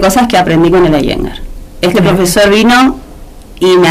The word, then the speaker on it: Iyengar: ¿Cómo es que se pronuncia? – Iyengar. – Iyengar, así aprendo. – Se escribe Iyengar. – Y yo digo así, pero cero, es... – Pero se pronuncia Iyengar.